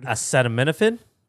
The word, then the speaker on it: acetaminophen: Acemenaphenin. Okay. Yeah, people love the... I think I got more responses to the... to me mispronunciation... Jesus Christ. Mispronouncing... – a word. – Acetaminophen. – There you go, babe.